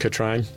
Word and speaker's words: Katrin: Katrina writes, Katrin, K-A-T-R-I-N. That's a weird name. – It's pronounced Katrine.